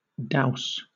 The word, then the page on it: douse: British English